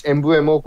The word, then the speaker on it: Mbeumo: I think it might have been Mbeumo, or I'm not sure if I'm pronouncing that correctly. – Yeah. It was Mbeumo who was playing out of position.